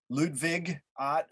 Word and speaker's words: Ludwig Ott: Ludwig Ott, Ludwig Ott, the German pronunciation, Fundamentals of Catholic Dogma.